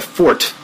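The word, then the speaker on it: forte: And I really wished I would have focused on my stand-up, because I think that was my strongest, that was my fort, as it's properly pronounced.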